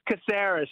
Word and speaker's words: Caceres: It's Caceres.